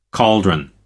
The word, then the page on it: caldron: American English